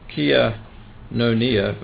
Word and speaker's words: koinonia: Most cases of fellowship are from the Greek Kianonia, if I pronounce that right, K-O-I-N-O-N-I-A, which according to Strong's can have many similar meanings: partnership, participation, communication, communion.